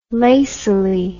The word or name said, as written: lacily